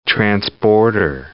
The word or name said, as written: transborder